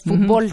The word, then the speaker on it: fútbol: En México lo pronunciamos como se pronunciaría en inglés, fútbol. – Fútbol. – Por eso es una palabra aguda que termina en L y no lleva acento. Pero en España, por ejemplo, dicen fútbol.